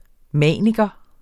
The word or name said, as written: maniker